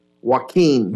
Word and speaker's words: Joaquin: Joaquin. – Joaquin, like the hurricane.